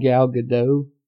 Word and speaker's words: Gal Gadot: Do you say the name Gal Gadot? Is that the way to pronounce it? – I think so.